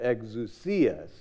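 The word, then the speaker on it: exousia (ἐξουσία): Or a lady last night, a Greek lady in our church, came up and said, no, exousia. So apparently that's how you say it.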